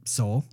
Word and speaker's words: Seoul: Seoul.